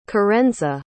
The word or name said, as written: karenza